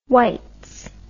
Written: Waitz

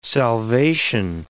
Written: salvation

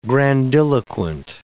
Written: grandiloquent

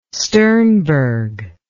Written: sternberg

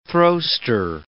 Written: throwster

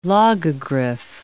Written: logogriph

